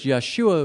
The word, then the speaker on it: Yeshua: that's a Greek pronunciation, but in Hebrew it's Yeshua, which means the Lord saves.